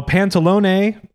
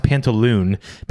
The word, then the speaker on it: Pantalone: So Pantalone, or I think as in English it's pronounced Pantaloon.